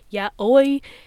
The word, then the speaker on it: yaoi: Everyone always says it's yaoi, but I always like said it was yoi, but now people are like, oh no, it's yaoi.